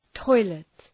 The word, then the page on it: toilet: ‘tɔılıt